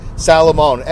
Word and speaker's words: Salamone: Just look up Doug Salamone. S-A-L-A-M-O-N-E. Sala, S-A-L-A, Moan, M-O-N-E, not M-O-A-N, silly.